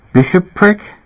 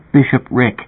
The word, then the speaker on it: bishopric: "Bishop prick?" – "No, Bishop Rick. You're not pronouncing it right."